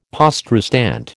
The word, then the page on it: poste restante: American English